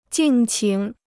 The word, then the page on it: 敬请: jìng qǐng